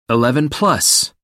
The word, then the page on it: eleven-plus: American English